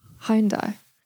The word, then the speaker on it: Hyundai: Hyundai. – Because haven't they changed the way? – No, I think that was just a marketing ploy to get people to remember Hyundai.